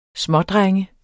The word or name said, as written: smådrenge